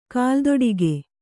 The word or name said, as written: ಕಾಲ್ದೊಡಿಗೆ